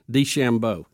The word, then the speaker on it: DeChambeau: Bryson DeChambeau.